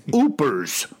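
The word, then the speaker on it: Yoopers: Well, you know, I got the correction from that fellow who wrote in. It's Oopers. – Oopers.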